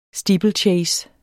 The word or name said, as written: steeplechase